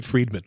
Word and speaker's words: Fridman: You know what I learned recently is that he pronounces it Friedman, which just adds insult to injury.